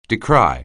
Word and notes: decry: anglais

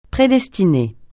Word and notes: prédestiner: francais

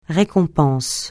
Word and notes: récompense: francais

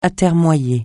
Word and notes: atermoyer: francais

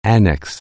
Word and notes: annex: anglais

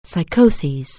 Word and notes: psychosis: anglais

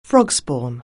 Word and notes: frogspawn: anglais

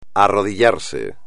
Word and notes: arrodillarse: espagnol

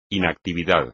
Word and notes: inactividad: espagnol

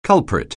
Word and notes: culprit: anglais

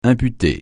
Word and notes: imputer: francais

